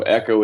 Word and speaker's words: EHCO: So Echo is E-H-C-O. It's an acronym for the Entertainment Helping Company. Obviously, we pronounce it Echo just like the standard Echo.